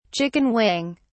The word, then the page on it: chicken wing: British English